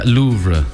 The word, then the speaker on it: Louvre: L-O-U. – L-O-U-V-R-E. Yeah, Louvre.